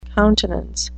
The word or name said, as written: countenance